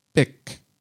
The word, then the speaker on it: Bích: Bích, kind of pronounced B-I-C, like the pen, but it is spelt B-I-C-H.